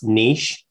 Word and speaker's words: niche: According to Merriam-Webster, there's a debate about how you're supposed to pronounce niche. There are two common pronunciation variants, both of which are currently considered correct. Niche, which rhymes with sheesh, and niche rhymes with pitch.